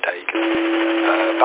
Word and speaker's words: Dagan: Yeah, the name this way is Dagan, which is an Irish name. Delta Alpha Yankee, Golf Alpha November. Phonetically a bit like Fagan, but with a D, Dagan.